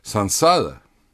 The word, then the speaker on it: сансара: Сансара, там ее можно произносить и через М, и через Н по-русски, потому что в середине сонорная, согласная, сансара. Сансара в переводе с санскрита – это блуждание.